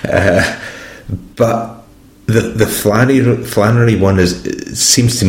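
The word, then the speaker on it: flânerie: method this idea of um, flanerie. Am I pronouncing it right? – Um, yeah.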